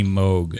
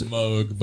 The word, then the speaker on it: Moog: So I think it's supposed to be pronounced mini Moog. – It is Moog, but Moog. I say Moog too because I'm American.